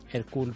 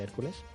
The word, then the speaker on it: Hercule: En el 74 la lleva al cine Sidney Lumet con Albert Finney haciendo del detective Hercule Poirot, que no se dice Hércules. – ¿Cómo? – Hercule, no. – Ah, no, es que es belga. Poirot.